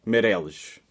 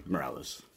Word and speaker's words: Mireles: But correct pronunciation in Portuguese, Mireles. In English, you could just say Mireles.